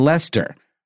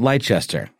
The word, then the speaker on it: Leicester: in Leicester, not Leicester, like you think it would be pronounced when you look at it on paper.